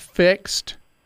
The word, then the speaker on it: FIXD: So are we talking about Fixed? Is that the one you looked at? – Yes. – All right. F-I-X-D, it's spelled.